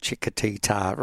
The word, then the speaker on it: Chiquitita: Chiquitita.